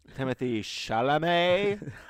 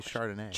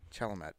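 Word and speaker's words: Timothée Chalamet: That and Timothy, how do you say his name? – Timothy Chalamet. – I was going to say Chardonnay. – Chalamet.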